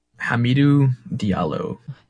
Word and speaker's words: Hamidou Diallo: Hamidou Diallo. – Hamidou Diallo, which I've seen some of his dunks.